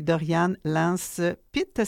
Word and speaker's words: Doriane Lance-Pitt: Qui met en scène Marie-Thérèse Fortin, la grande Marie-Thérèse Fortin, Alexandra Gagné-Lavoie, Jacques Laroche, Louis Lorrain et Doriane Lance-Pitt. C'est comme ça qu'on prononce? – Oui, puis c'est François-Louis Lorrain.